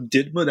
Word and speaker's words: DIDMA: So, in 1980, Congress passes a law called, and it's pronounced sort of DIDMA. That's the shorthand for it.